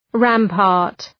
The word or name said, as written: rampart